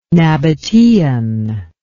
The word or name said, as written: nabataean